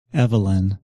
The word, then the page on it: Evelyn: English